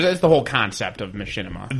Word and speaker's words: Machinima: Do any of you guys keep track of, I'm going to butcher the name, it's Machinima or Machinima? – It's Machinima.